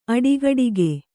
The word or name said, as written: ಅಡಿಗಡಿಗೆ